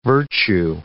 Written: virtue

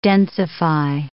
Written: densify

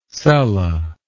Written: sella

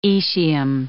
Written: aecium

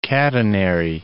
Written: catenary

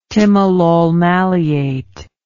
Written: timolol maleate

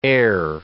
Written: ere

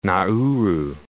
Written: Nauru

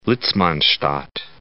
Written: Litzmannstadt

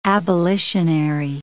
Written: abolitionary

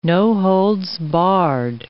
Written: no-holds-barred

